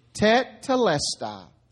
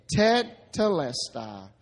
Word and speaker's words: tetelestai: Tetelestai. Say it. Tetelestai.